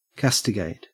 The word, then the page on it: castigate: Australian English